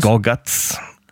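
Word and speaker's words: Gorguts: Gorgat, äh, Gorgat, wie spricht man sie aus? Gorgats. Gorgats, äh, Iron Dissonance und whatnot dass das halt auch alle Bands sind, die sind teilweise so extrem hart und Chaos und so, ne?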